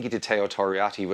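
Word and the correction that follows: Teo Torriatte: which was, excuse me, am I pronouncing that right? Teo Toriate.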